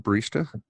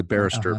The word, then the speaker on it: barista: The barista. – Okay. Uh-huh. I just want to make sure I pronounce that right. I was going to say the barrister, uh-huh. But that's more of a legal person.